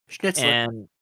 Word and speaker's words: Schnitzler: but yeah, Traum Nouvelle, it's a book written by some, I think, German guy named Arthur Schnitzler. I think that's how you say his name. His name. – Yeah, that's it. Schnitzler.